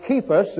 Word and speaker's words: Cephas: I, of Cephas, as that should be pronounced with a hard K sound, that is the word for Peter.